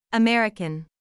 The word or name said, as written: American